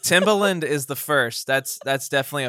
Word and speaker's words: Timbaland: He is now this incredible, as far as I'm concerned, the Timbaland of, did I say that right? – That's right. Yeah. Yeah. Timbaland.